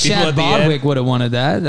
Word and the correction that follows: Boseman: No, Chad Boseman.